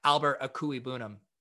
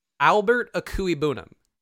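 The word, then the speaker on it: Albert Okwuegbunam: So I do know how to pronounce Albert Akui Bunum. – Albert Akui Bunum. – Yes. Okay. There are some words or letters in there that are silent, but that is the phonetic pronunciation.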